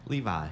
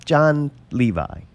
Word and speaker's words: Levi: So I watched John Levy. I call it John Levy. – Levi. – But it's Levi, Levy? Right? John Levi. – How do you spell it? – L-E-V-I.